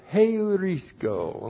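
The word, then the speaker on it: heurisko (εὑρίσκω): And then we have the verb heurisko. And we've had this verb a lot of different places in the Bible. H-E-U-R-I-S-K long O.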